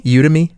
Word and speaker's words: Udemy: How do you say it? Udemy? – Udemy? – No. That's how I'd say it. – I'll say Udemy.